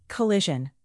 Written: collision

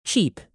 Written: cheap

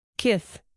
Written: kith

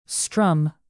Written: strum

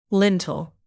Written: lintel

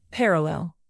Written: parallel

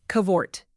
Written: cavort

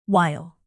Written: wile

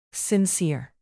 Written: sincere

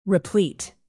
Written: replete

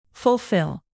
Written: fulfill

